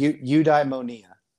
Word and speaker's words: eudaimonia: As far as near the end of your book, it really focuses on this Greek term, and I'm going to butcher it, so maybe help me out here. – Yeah, but eudaimonia. – Eudaimonia. Okay. – Yes.